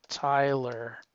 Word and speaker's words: Tyler: That's spelled differently, though. You said Taylor. It's Tim Tyler.